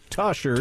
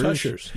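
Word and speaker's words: Tushar: It's pronounced Tushers. – Tushers, yeah. That's what I said before.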